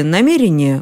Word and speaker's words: намерение: Одни слова исчезали, другие появлялись, но ударение в слове «намерение» было таковым и во времена Даля, то есть и в середине XIX века. Намерение, только намерение.